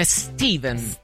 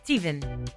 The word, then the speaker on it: Stephen: Io quello che non ho mai capito è perché si chiama, cioè tutti chiamano Stephen King, dicono tutti Stephen King invece di Stephen King. Cioè, scritto STFHE, vabbè, insomma, quello che a me mi sembra più Stephen che Steven. – Però la pronuncia è Steven.